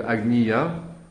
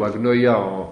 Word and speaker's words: agnoia: C'est aussi un des sens du terme grec de agnia. Agnia ou agnoia en prononciation universitaire, ça peut être la docte ignorance, mais ça peut être aussi un péché, c'est-à-dire le mépris.